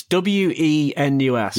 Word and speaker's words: Wenus: So the way that that it's spelt out is W E N U S, yeah. right? – That is not how you would say that word. How would you say it? Wenus.